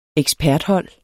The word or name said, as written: eksperthold